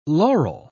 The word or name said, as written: loral